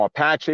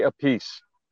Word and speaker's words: Appice: You know, there's all these different uh, pronunciations, and uh, you know, Apache, Appice, Appice, blah, blah, blah. So Carmine just said, "Just say a piece."